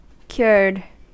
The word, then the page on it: cured: American English